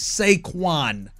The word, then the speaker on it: Saquon: You said Saquon. It's Saquon Barkley. You said that incorrectly.